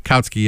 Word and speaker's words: Kautsky: Who's the guy, the socialist that became like social democrat, that Kautsky, is it, am I pronouncing the name right? – Well, there's a whole millions of them, not millions, but I'm sorry, there's many of them. Kautsky, yeah.